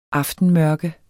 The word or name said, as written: aftenmørke